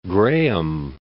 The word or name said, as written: Graham Land